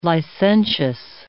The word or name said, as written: licentious